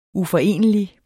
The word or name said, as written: uforenelig